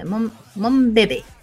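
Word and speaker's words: Monbebe: En la club de fandom se pronuncian como eh, Mon, Bebé. Ah, significa. ¿Por qué? Porque Mon significa mi y bebé significa bebé en francés.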